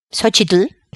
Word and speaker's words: Xochitl: Xochitl la pronunciación adecuada.